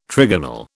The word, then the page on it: trigonal: British English